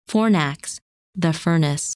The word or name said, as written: Fornax